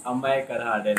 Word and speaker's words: Amey Karhade: It's Amey Karhade, though.